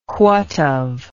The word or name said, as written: whatve